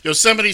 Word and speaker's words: Yosemite: when they gaze upon Yosemites, towering sequoias. – Now, as we all know, it's pronounced Yosemite.